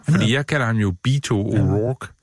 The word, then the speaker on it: Beto: at vi udtalte det forkert. Og så tænkte jeg, det gør jeg ikke, for jeg synes, jeg havde hørt det på amerikansk TV, og nu har jeg undersøgt det. Det er Beto, og ikke Beto.